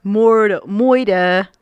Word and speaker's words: moider: They spell it M-O-I-D-E-R. And they would jokingly say, moida. Like, but they would say it like a Brooklyn accent.